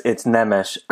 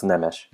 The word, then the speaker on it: Nemes: No, it's Nemes. I'm Romanian, but my name is Hungarian, and it's pronounced Nemes.